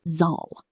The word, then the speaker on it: Saal: A long A sounds like the R in father. Think of the word Saal, meaning hall, nice and long.